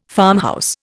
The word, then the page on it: farmhouse: Indian